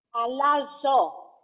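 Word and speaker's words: Alazo (ἀλλάσσω): How do you pronounce this word here? – Alazo. – Alazo. – Alazo. – Alazo. – Yeah. – Okay. Excellent.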